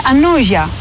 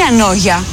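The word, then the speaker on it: Ανώγεια: Είναι και το σι, είναι σιρινό, είναι και το γάμα, είναι ανόγια, δεν είναι ανόγια, είναι ανόγια. Και εγώ επειδή είμαι από χωριό,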